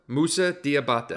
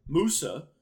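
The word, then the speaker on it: Moussa Diabaté: And I saw on the pronunciation chart, Moussa. That's how you say it.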